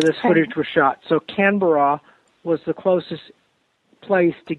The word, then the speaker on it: Canberra: I stood a good chunk of the time in Sydney, and then I went to, I'm probably saying it wrong, Canberra. – Canberra.